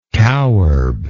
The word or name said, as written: cowherb